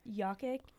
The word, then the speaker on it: Jokic: But I would definitely say that Jokic, that's how you say it.